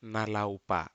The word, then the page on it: nalaupa: Aneityum